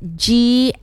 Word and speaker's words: glossophobia: So it's spelled GLOSSOPHOBIA, mm-hmm, glossophobia.